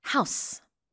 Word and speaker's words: house: 집 얘기할 때는 S 발음이 더 강해집니다. House. 근데 동사할 때는 house. 스펠링은 똑같아요.